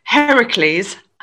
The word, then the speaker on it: Heracles: Okay, so apart from the 12 labors, Heracles I said it right this time.